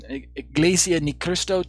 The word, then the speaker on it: Iglesia ni Cristo: like Iglesia de Ni Cristo teach, I keep butchering that name, Iglesia Ni Cristo teach,